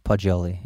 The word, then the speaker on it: Poggioli: Soft G, Poggioli, yeah.